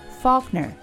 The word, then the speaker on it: Faulkner: J-E-A-N-N-E Faulkner, like the writer, F-A-U-L-K-N-E-R.